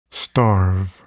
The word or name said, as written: starve